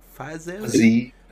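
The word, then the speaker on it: Fiziev: If not, Rafael, how you say it? Fazayev? – Fazayev.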